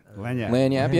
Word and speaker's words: lagniappe: But, I thought it was a lagniappe. Thing. Is it, did I say that right? – Lanyap, yeah.